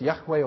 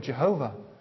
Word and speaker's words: Yahweh: And that's because the original word in the Hebrew is the word Yahweh, or the four letters YHWH, pronounced Yahweh or Jehovah.